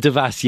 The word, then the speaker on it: Devas: Oh, no, I'm Devas, yeah. I'm British, but it is a French name.